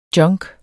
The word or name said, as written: junk